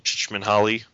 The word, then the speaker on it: Csikszentmihalyi: But Mihaly Csikszentmihalyi.